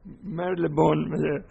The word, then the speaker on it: Marylebone: Marylebone, so yes, it's not my native language, of course.